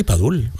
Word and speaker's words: Padul: Tengo también Padul... Padul, sí, no sé si lo digo bien, perdonadme. – Sí, Padul, Padul, Granada.